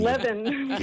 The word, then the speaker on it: Levin: And the word council, it makes me think of the Council of Heaven, and that your name, your last name rhymes with heaven. The leaven of heaven. – Leaven.